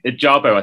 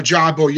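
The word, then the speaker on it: Ojabo: Ajabo, I think is pronounced. – Ajabo, yeah.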